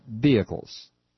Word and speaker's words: vehicles: You don't pronounce the H, chuckle nuts. Trust me on this. It's vehicles. Hybrid vehicles.